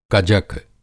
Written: कजक

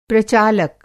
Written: प्रचालक

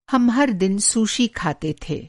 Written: सुशी

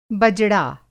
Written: बजड़ा

